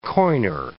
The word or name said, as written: coiner